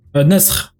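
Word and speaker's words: naskh: Bien que euh, le, comment, le concept de, je je sais pas comment on dit en arabe, nesirat ou nesirat, je sais pas bien le prononcer, mm. abrogation. – nasr. – Un Nesr, voilà, merci, je merci.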